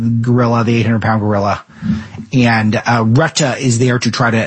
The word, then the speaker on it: Rutte: And just remember, currently NATO Secretary General Mark Rutte, I think it's pronounced Retta, welcome the remarks.